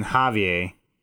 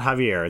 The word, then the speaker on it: Javier: So Oliver, August, and Javier, because it's not Javier, it's Javier. That's how they pronounce it.